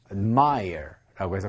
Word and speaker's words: admire: 자, 발음은 자, admire라고 해서 강세는 이제 뒤에 있기 때문에 실제로는 저 A의 발음이 잘 들리지 않는 경우도 많이 있습니다. ad 이렇게 발음하는 것이 아니라 admire 이렇게 이제 발음하기 때문이죠.